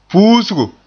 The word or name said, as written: puusgo